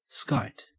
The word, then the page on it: skite: Australian English